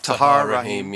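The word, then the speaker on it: Tahar Rahim: Tahir Rahim, yeah.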